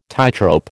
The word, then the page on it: tightrope: American English